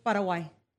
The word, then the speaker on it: Paraguay: Paraguay.